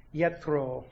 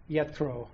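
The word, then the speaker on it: Yitro: Probably more accurately pronounced, Yetro, Yetro.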